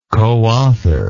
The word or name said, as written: coauthor